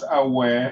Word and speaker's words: Aoaeh: It's, it's pronounced Awe.